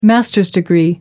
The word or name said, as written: master's degree